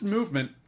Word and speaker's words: MVMT: MVMT watches, pronounced movement. If you were wondering, they took the vowels out. It's pronounced movement watches.